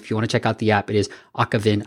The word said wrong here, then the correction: Avakin: I feel like I'm saying it wrong, Oliver, but Akavin Life, like Avatar, Kin. – Avakin. – Avakin Life. See, it's the emphasis that I'm putting it on too much. But Avakin Life, go check it out in the app stores.